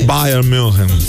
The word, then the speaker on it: Bayern München: Bayern München.